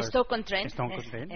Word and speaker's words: Stoke-on-Trent: Plena confianza que le hace falta también al City, y Begoña, que empezó muy bien, pero empató en campo del Stoke-on-Trent, ¿lo he dicho bien? ¿Stoke-on-Trent? – Sí, el Stoke City, en Stoke-on-Trent, es el nombre de la localidad.